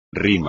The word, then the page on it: rima: espagnol